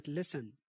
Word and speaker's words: listen: حرف T في كلمة listen, often, whistle, glisten, fasten.